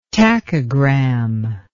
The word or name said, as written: tachogram